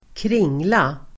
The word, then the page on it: kringla: [²kr'ing:la]